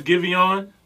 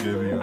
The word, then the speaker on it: Giveon: The artist is Giveon. – That's how you pronounce it? Okay, I'm glad you said it. – Giveon.